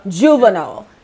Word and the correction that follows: Jovenel: Jovenel. – Jovenel. See, you see.